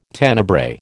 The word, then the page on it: Tenebrae: American English